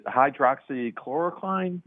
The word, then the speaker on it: hydroxychloroquine: One of the other controversies I'd like to ask our guests about is around the is it hydroxychloroquine?